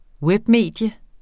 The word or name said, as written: webmedie